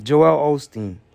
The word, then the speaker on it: Joel Osteen: Yeah, Joel Osteen.